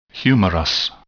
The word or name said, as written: humerus